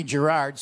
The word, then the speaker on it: Girard: Meanwhile, Connie Girard, soft G. Connie Girard? Oh, how was a soft G in Girard?